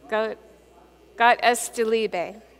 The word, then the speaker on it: Gott ist die Liebe: And in German, some of you may be able to say this better than me, but it's Gott ist De Liebe? Gott ist die Liebe.